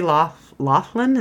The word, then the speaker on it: Loughlin: It was so important to Lori Loughlin, is that how you say her name? – Yeah, I think so.